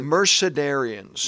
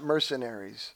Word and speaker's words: Mercedarians: Mercedarians, which a lot of people mispronounce, by the way. – That's true. – Mercedarians. – Not mercenaries.